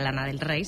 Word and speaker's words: Lana Del Rey: En realidad en inglés sería Lana del Rey, se pronunciaría así, pero era con A.